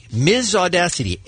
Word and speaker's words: Myzaudasity: And finally, and I can't believe I'm going to try to pronounce this, Ms. Audacity. Ms. Audacity. M-Y-Z-A-U-D-A-S-I-T-Y.